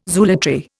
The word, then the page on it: zoolatry: Indian